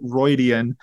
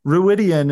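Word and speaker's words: Rhuidean: It will take us to places like the Aiel Waste, Roydian, which is how they say it in the audiobooks, tropical city of Tanchico. Why are those places interesting? – All right. So some of the audiobook pronunciations, I have to wonder if this is before the time of really giving pronunciation guides, because I've never heard someone call it Roidian. Ruidian is always the name I've heard it pronounced.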